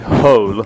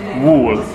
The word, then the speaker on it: whole: Because Honza's problem is that he sometimes mispronounces this word, and he says wool, which is obviously material, which we use for it comes from sheep, doesn't it? – Okay.